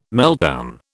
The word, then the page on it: meltdown: British English